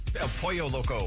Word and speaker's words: El Pollo Loco: El Pollo Yoko. You can get a free chicken taco. Did I say that right? – El Pollo Loco.